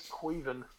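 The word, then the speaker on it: Caoimhín: Because somehow you pronounce that dude's name as Queven. – Yes. – It's the Irish.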